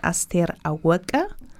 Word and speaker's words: Aster Aweke: Sie heißt Aster Aweke. Aster Aweke. Aweke.